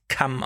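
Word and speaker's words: Cham: Spricht man übrigens Kamm aus, also mit K.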